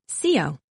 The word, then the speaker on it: Xio: For short, I just go by X-I-O, pronounced Sio. It rhymes with Leo, my sign.